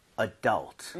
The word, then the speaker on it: adult: Adult, right? – 네. – 자, 강세를요. U에다가 찍고 아. 발음을 하면 좋습니다.